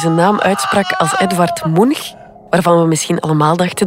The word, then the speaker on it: Munch: Edvard Munch, zo moeten we het uitspreken.